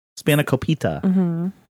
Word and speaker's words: Spanakopita: Spanakopita is the correct pronunciation there.